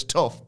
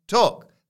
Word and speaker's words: Tough: T-O-U-G-H. – What? – Yeah, that's what I thought. I read it as Angus Tuff, but Angus is written Tuck, pronounced like Tuck.